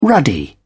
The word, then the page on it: ruddy: British English